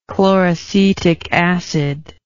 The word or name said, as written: chloracetic acid